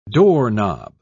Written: doorknob

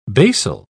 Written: basal

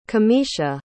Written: camishia

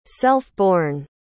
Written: self-born

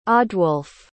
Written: odwolfe

